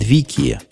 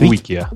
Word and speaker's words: Wikia: Ну вот если с джифоном тоже это слухи будут, не будут, с Викия. Ее так произносит Викия. С Wikia, да. Wikia.